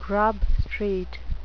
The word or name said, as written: grub street